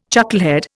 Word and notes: chucklehead: Indian

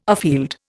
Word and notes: afield: Indian

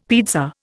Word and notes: pizza: Indian

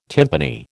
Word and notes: timpani: American English

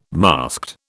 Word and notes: masked: British English

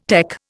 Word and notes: tec: Indian

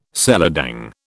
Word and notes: seladang: British English

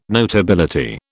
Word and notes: notability: British English

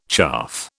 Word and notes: chaff: British English

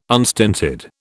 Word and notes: unstinted: British English

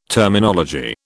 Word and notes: Terminology: British English

